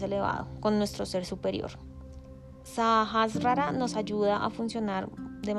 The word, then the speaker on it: Sahasrara: Sajas rara, lo pronuncié mal, Sajas rara, rara,